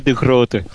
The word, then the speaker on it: De Groot: Do I pronounce it like that, De Groot? – Yes, it's more or less like that. It's Ronnie De Groot.